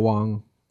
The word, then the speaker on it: Wang: Or it's actually, I pronounce Garrett Wong. Wong.